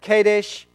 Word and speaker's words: Kadesh: at Kadesh, actually is how you say that, and they brought back word to them of all and to all the congregation and showed them the fruit of the land.